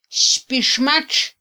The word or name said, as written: xpixmatx